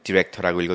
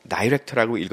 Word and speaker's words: director: 그러면 이걸 읽을 때는 디렉터라고 읽어도 되고 다이렉터라고 읽어도 됩니다.